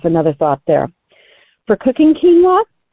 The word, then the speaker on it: quinoa: Quinoa, that's spelled Q-U-I-N-O-A. And it is pronounced quinoa.